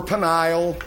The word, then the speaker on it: Peniel: Who is that Penuel? Or Peniel, you can pronounce it that way.